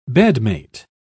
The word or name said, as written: bedmate